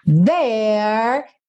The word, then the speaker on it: there: There, a letra E do final é pintada de cinza, então você não vai pronunciar.